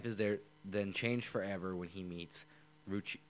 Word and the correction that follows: Rukia Kuchiki: I butchered that name so hard. I apologize. Rukia Kuchiki. – Okay, so. – I tried to apparently mix the two names.